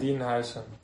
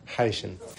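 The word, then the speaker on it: Huijsen: Dean Housen. – Housen, vale. – Housen, yo creo que dice Housen, H aspirada.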